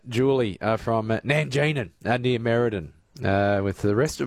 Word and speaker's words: Nangeenan: And good on you and your brother, the Nanganine Town Hall. – So, no, it's not Nanganine, it's Nanjean, and it's like N-A-N-G-E-E-N-A-N.